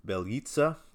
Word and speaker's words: Bjelica: Uh, Nemanja Bel- Bjelica, which uh, I'm good at pronouncing that because he was supposed to be a Sixer, that asshole.